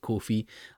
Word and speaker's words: Ko-fi: I had a look and they pronounce it Ko-fi.